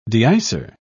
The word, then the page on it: deicer: British English